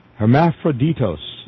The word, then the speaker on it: Hermaphroditos: The transsexual who writes this letter says a group of New York androgynes, or androgynes, meaning androgynous, organized what was called the Hermaphrodites. Not hermaphrodites, it's spelled differently. I'm not equal to pronouncing some of this. Hermaphroditos, that's it, Hermaphroditos.